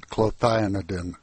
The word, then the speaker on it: clothianidin: to talk about a pesticide that's known as clothianidin. Now, how did I do on that name? – Well, I think there are probably variations. I pronounce it clothianidin.